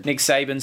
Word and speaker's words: Saban: Nick Saban.